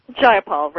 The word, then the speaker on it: Jayapal: We're talking with Pramila Jayapal. Am I pronouncing that right? – Jayapal, right.